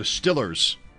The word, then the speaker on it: Steelers: The Steelers, or the Stillers, as they're known in some parts. Sunday at 1. – What parts are these? – Pittsburgh. – Yeah. – That's how they pronounce Stillers. – It. Stillers. Hmm.